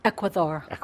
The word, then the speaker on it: Ecuador: Ecuador. They don't say D like a doorknob.